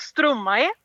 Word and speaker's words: Stromae: Ja, jag hade ju samma problem där, men jag tror att artisten uttalas Stromae.